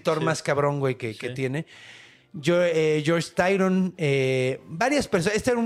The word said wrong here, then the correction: Tryon: Eh, Sir George Tyron también vio, Tryon,